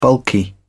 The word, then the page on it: bulky: British English